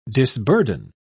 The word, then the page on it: disburden: British English